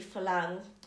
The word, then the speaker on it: Farang: So it's spelled Farang, but in Thai it's actually Falang.